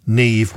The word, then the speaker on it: Niamh: People don't know how to pronounce that because it's spelled S-I-O-B-H-A-N. Aoibhan. Do you know how to spell Aoife? A-O-I-F-E. Or Neve. That's another difficult one. If you're not used to it, Neve, which is spelt N-I-A-M-H.